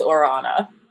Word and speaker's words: Ora Ana: I love that you pronounce the name correctly because I get all types of things, but it is Ora Ana.